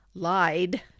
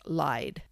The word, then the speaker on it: Lyde: but it's Lyde, according to pronounce this name.